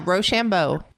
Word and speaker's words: Rochambeau: How do you pronounce Rochambeau? Is that, am I saying that right? – Yeah, Rochambeau.